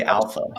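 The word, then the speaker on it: ALPHV: Anyway, so speaking of trust, so you mentioned Alf-V and Black Cat just a bit ago. – Alpha. – Yeah. Is that, how do you pronounce like Alpha? – It's technically Alpha. Yeah, that's how they pronounce it.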